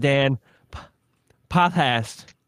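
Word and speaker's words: Dan Potthast: So, yeah, we've got Dan Pothast. God damn, I'm so bad at words. – We got Dan Pothast. – I've been saying it wrong for fucking 15 years, you know? It's hard to, you gotta retrain the tongue.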